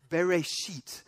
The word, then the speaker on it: bereshit: bereshit, which is a combination of two things, in and the beginnings.